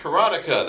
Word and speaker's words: Karateka: I was hearing people call him Mechner, and they were correcting each other on Karateka. It's pronounced Karateka.